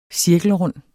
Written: cirkelrund

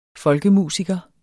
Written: folkemusiker

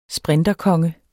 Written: sprinterkonge

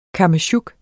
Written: kammesjuk

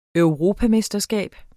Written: EUROPAMESTERSKAB

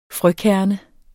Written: frøkerne

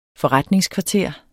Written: forretningskvarter

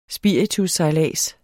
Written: spiritussejlads

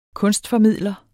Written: kunstformidler

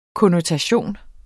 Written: konnotation

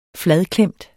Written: fladklemt

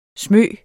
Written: smyge

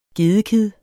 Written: gedekid

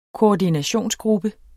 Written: koordinationsgruppe

